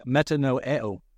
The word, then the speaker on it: metanoeo: The original Greek word in the New Testament, which we translate repentance, is pronounced metanoeo.